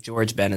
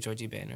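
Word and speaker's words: Jorge Ben: This guy, it's like J-O-R-G-G-E and then B-E-N, like Ben, like George Ben is what it like. Looks okay. – Like in American. – Okay. – But I think it's pronounced like Georgie Ben or something like that.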